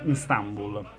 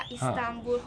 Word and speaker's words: Istanbul: Come mai sei finita a Istanbul?